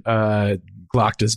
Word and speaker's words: Glokta: Is it Glock? How do you pronounce Glockta. – It? – Glockta. – Glockta. – Glockta. Yeah. Yeah, Glockta.